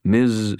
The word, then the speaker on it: Ms.: Ms. Stern, I said in a low, grave voice, extra emphasis on the Z in Ms. I wonder if you might have a few moments.